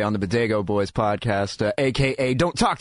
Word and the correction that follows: Bodega: Yeah, true. Bodega. There's a good pronunciation guide in Half-Baked if you need it. – Did I say Bodego? – You did say Bodego.